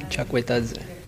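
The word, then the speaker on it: Chakvetadze: Vielleicht hören wir einfach mal rein, wie er denn ausgesprochen wird. So, also zumindest so spricht er sich selber aus. – Georgi Chakvitaze.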